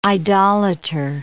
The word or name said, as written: idolator